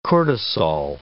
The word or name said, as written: cortisol